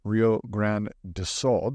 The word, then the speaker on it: Rio Grande do Sul: The floods in the Brazilian state of Rio Grande do Sul. Did I say that right this time? – You said that right. Good job.